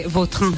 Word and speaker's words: Vautrin: Pues, es que si me pregunta exactamente cómo se pronuncia, pues así. Claro, pero sí. Yo ya soy Vautrin. – Ya. – Está españolizado total.